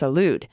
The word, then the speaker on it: Salud: Carbajal, my rep's first name, is pronounced Salud.